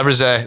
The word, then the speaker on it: Faberge: It's Fabric. I can't say it right, but that's a... – Faberge. – Yeah. – Russia. – Yes. – Yeah, that's probably right. – Faberge. Wow. – Faberge. That's it. Yeah.